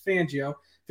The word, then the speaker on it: Fangio: I always say that name wrong. People get mad. I say Fangio. It's Fangio. Vic Fangio.